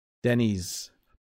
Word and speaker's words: Denny's: Do you pronounce an S or a Z at the end? So that's another difference between Canadians and Americans. We say X, Y, Z when we say the alphabet. Americans say X, Y, Z. I say Denny's. It's probably a mix of the two. Okay. I don't say Denny's or Denny's. Denny's. Yeah, it's definitely a soft S into a Z. So it does have a Z feeling to it.